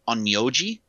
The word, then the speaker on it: Onmyoji: All her many powers combined imply she's an Onmyoji, I think I said that right? – Yeah.